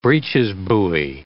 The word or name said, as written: breeches buoy